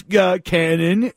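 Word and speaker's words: Kannon: Hey, my name's Cannon. That's pronounced with a K.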